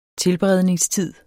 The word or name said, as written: tilberedningstid